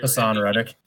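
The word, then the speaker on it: Haason Reddick: For your Hassan Reddick. – Okay, it is Hassan. There's two A's in there. It threw me off.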